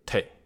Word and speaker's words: 茶: 因为在这两个口音之中，对“ 茶” 这个字的发音仍然还是古老的发音方法，也就是极为贴近荼毒生灵的“ 荼”，那就是 “te”，肉骨茶把骨 te 的 “te”。